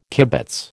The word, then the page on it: kibitz: American English